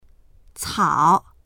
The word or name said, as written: cao3